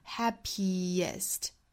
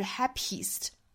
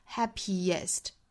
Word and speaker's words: happiest: happiest 而不是 happiest 很多同学会读成 happiest 应该是 happiest happy 尾部是一个紧音，后面是松口的 a 的音，两个元音不同，我们不能混为一谈啊，要中间加一个小辅音的 happiest。